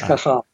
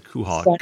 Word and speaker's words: Cajal: That's pronounced Cajal. – Cajal. I said it a little bit wrong. I said Kuhal.